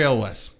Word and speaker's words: Elwes: Elwes? Elwes? – Yeah. – Cary Elwes.